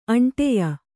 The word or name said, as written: ಅಂಟೆಯ